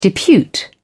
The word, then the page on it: depute: British English